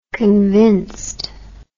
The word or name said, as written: convincedness